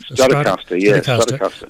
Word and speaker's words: Stratocaster: And you thought, that guitar that he's playing, that Stratocaster, is that how it's pronounced? – Stratocaster.